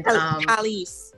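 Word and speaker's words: Kelis: So some of the lyrics from, I think I've always pronounced her name, the artist's name wrong, but it's K-E-L-I-S. And Kellis.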